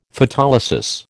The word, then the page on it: Photolysis: American English